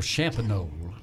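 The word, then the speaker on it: Champagnolle: It's called Champagnole, and it's C-H-A-M-P-A-G-N-O-L-L-E.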